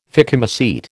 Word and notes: phycomycete: American English

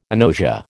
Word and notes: agnosia: American English

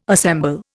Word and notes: assemble: Indian

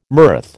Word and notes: mirth: American English

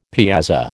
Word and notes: piazza: American English

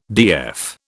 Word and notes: DF: British English

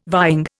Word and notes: vying: Indian

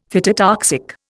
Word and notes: phytotoxic: Indian

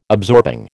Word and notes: absorbing: American English

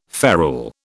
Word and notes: ferule: British English

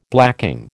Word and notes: blacking: American English

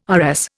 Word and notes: RS: Indian

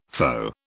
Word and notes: foe: British English

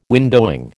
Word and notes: windowing: American English

